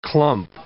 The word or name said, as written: clump